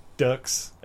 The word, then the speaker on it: Frank Dukes: And Frank Dukes. I keep saying Ducks because that's how it's spelled.